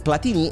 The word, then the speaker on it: Platini: L'accento sulla I finale di Platini e la francesizzazione di un cognome italiano, italianissimo,